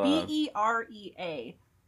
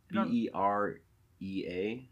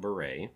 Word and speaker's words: Berea: It is not beret. Nope. looks, looks like it is Berea.